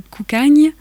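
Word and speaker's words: Cocanha: J'étais ravie parce qu'on entend le duo Koukagne. Je ne sais pas si je l'ai bien prononcé. – Oui, c'est bien comme ça.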